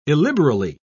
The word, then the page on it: illiberally: British English